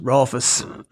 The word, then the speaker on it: Rolfes: Well, I hate to correct people right on the get-go, but my name is pronounced Rolfes.